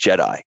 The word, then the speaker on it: JEDAI: Well, so JEDI, since this is an audio format and you can't necessarily see how we spell it, is JEDAI, which stands for the Joint Enterprise Data and AI Platform, and pronounced JEDI.